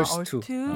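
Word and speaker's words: Earth2: 러 어, 스 어스, 어스2. 지구 아. 두 번째 지구 아, 얼스2. 네. 아, 발음이 아, 거기 들어가면막다 살 수 있습니까? – 네.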